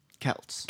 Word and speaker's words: Celts: Is it Celts or Celts? – Celts. – Celts, that's what I thought.